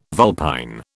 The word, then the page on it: vulpine: British English